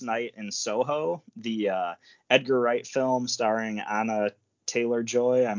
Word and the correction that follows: Anya: Anya, this, but yes, I hear you.